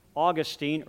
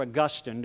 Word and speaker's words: Augustine: His name, Augustine or Augustine, depending on how you want to pronounce it.